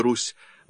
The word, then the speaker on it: Русь: «С» в слове «Русь» должно произноситься мягко.